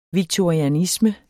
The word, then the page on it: victorianisme: vigtoɐ̯iaˈnismə; vigtʌia-